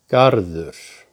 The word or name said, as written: Garður